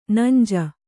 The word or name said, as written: ನಂಜೆ